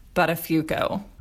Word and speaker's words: Buttafuoco: We can spend this whole episode, and will, talking about all of Joey's poor choices, but his name is actually pronounced Buttafuoco.